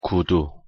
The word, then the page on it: 구두: Korean